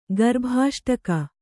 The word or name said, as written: ಗರ್ಭಾಷ್ಟಕ